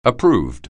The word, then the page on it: approved: anglais